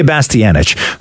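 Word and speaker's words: Bastianich: Lydia Bastianic, who does Lydia's Kitchen.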